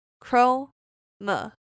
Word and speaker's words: chromosome: 那我们第一个发音重点是 chromosome，也就是我们的染色体。那这边 chromosome 分三个部分，chromosome，前面的 chrome 就是我们常用 Google 搜寻引擎，那也就是 Chrome 一样的发音。那第二个音节就是 m，那第三个音节是 zone，所以念在一起就变 chromosome。